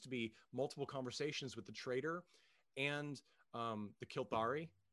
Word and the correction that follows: Kilrathi: Am I saying that right? – Kilrathi. – Hey, okay, Kilrathi.